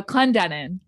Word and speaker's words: Clendenin: I say your last name right? – Clendenin. – Clendenin. Come on.